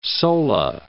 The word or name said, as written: sola